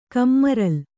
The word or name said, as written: ಕಮ್ಮರಲ್